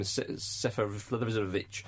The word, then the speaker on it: Seferovic: Jones let I forget his name, Seferovic in, and I think you'll find that's correct pronunciation.